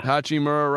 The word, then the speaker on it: Hachimura: Hachimura, right.